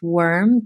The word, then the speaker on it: Worme: I would say one of my current favorites as well, I believe it's pronounced Worm, W-O-R-M-E, Worm.